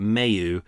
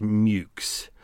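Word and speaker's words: Meux: For anyone who's a pedantic out there, I know that Mayhew is actually pronounced Mukes. I deliberately went through and went, oh, it's pronounced Mayhew & Co. because it's M-E-U-X.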